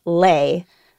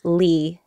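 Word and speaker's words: Le: So the traditional pronunciation of the last name L-E is Lay, but I'm used to calling myself Lee,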